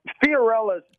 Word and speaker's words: Fiorella's: Fiorella's, it's called Fiorella's Jack Stack.